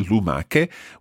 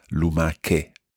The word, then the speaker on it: lumache: The singular, lumaca, is spelled L-U-M-A-C-A. And when that becomes the plural, lumache, we have to add an H in there in order to keep the hard sound of lumaca, lumache.